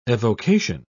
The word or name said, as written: evocation